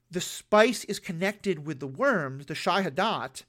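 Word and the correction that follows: Shai-Hulud: I'm probably pronouncing that wrong. – Shai Hulud, thank you.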